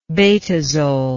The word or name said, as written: betazole